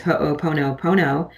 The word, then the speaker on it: Ho'oponopono: And in your book, you talk about, and forgive me if I'm mispronouncing this, I think it's Ho'oponopono. – Perfect. – Oh, great. Thank goodness. I wasn't sure. – No, that's great. Ho'o means to make, and pono means right.